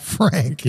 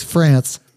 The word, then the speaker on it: Franc: Well, you spell it, right? And it's phonetic and you go Franc. And I can still see her dad smiling and laughing at me and going, it's not Franc. – Yeah. – It's France.